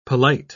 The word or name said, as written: polite